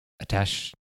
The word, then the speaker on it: attaché: Attaché.